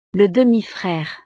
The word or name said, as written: le demi-frere